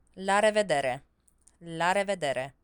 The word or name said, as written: la revedere